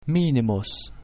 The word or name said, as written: minimus